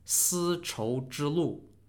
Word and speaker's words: 丝绸之路: This was all thanks to a network of trade routes called the Silk Road, or pronounced in Chinese as 丝绸之路,